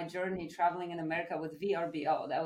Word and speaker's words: Vrbo: VRBO, which now they changed the way you pronounce it to Vrbo, but back in the day, it was VRBO.